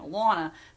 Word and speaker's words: Elana: And it's pretty funny, at our first meeting he said to me, Elana, because he's from Boston. And that's how I pronounced my name, Elana.